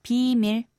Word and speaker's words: 비밀: The key here is to say the first syllable long and keep the second one short and say 비밀.